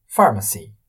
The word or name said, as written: Pharmacy